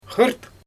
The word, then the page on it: xɯrt: Xyzyl